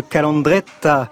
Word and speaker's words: calandretta: Nous étions dans une calandretta, une école où l'on apprend euh, l'occitan. Je l'ai bien prononcé. – Oui, tout à fait. C'est surtout l'accent, la calandrette, l'accent du midi.